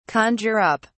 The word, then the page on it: conjure up: British English